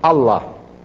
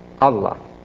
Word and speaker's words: Allah: was was made a compound word, pronounced Allah, Allah, meaning the God, the God.